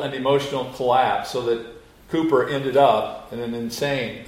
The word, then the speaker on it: Cowper: Now, if you know of his hymns, you will know that his name is spelled like you would pronounce it Cowper. But I understand it's correctly pronounced Cooper.